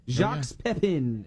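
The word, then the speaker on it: Jacques Pépin: And that Jacques guy, I didn't realize this, but I've seen some of his cooking like TV shows, but Jacques Pepin, if I'm saying that right.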